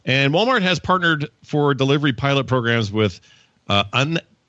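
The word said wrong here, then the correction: Udelv: U-delve. – Am I saying that right? I think U-delve. – So. Undelve? – U-delve. – Undelve. – Like you. – U-delve. Yeah, like me, like you.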